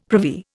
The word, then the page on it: privy: Indian